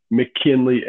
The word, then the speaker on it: McKinley: My name's Bryce, B-R-Y-C-E, McKinley, M-C-K-I-N-L-E-Y.